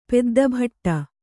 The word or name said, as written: ಪೆದ್ದಭಟ್ಟ